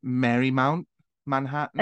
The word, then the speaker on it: Marymount Manhattan: my initial thing is to say Marymount Manhattan.